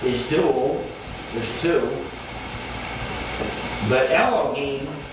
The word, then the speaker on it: Elohim: The word there is Elohim, E-L-O-H-I-M, Elohim.